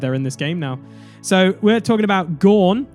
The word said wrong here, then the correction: Gnaw: It's Gnaw. Gnawing on a bone.